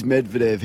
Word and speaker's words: Medvedev: The only Russian name I think I can pronounce, Medvedev. – It is Medvedev.